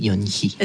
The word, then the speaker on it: Yon-hi: Oui, Yon-hi. – Que je le prononce bien? – Oui, c'est bien.